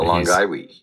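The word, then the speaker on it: Longaevi: I did classical, and so I think that would be long guy we, and in medieval it would be long guy we.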